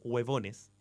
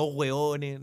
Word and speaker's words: huevones: Ella tiene que haber dicho, en todo caso, huevones, pronuncia bien la V. No hueones, no están, nunca la han rota, boy, pos hombre.